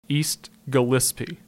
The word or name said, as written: East Gillespie